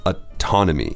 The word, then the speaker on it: autonomy: So it looks like the word automatic, but we pronounce it differently. – It's a little bit, different. It's autonomy. – Mm-hmm. Yeah, you stress the second syllable, autonomy.